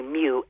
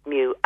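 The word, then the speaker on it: mu (μ): So the MG is milligrams and the UG is actually, it's actually mu. It sounds like the cat mu. – Okay. – That's how it's pronounced. It looks like a U but pronounced mu, and that's again, 1,000th less.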